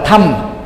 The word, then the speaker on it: Dhamma: tương tự sanga thì đọc là săn dhamma thì gọi là thăm